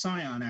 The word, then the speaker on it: scion: The word is pronounced scion, actually, as in son.